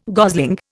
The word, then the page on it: gosling: Indian